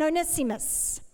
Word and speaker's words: Onesimus: Now, I looked this up, and I listened to how to pronounce these names, Tychicus and Onesimus.